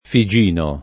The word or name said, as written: Figino